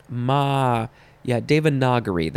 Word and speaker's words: Devanagari: Devanagari, noun from 1781, and actually it's the N-A part that's throwing me off. So let me check this little pronunciation guide. Devanagari, that's it.